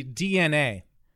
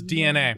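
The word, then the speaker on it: DeNA: One. It's actually DNA. – Oh, what the fuck? – Yeah, it's DNA. – Why would you print it like that if it was meant to be pronounced DNA? – I think I didn't capitalize the A. But it's like D-E, and then N-A.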